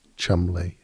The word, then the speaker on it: Cholmondeley: or Cholmondeley is pronounced Chumley, or Magdalene is pronounced Magdalene, and Worcester is pronounced Worcester.